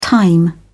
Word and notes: thyme: British English

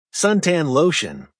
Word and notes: suntan lotion: American English

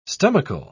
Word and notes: stomachal: British English

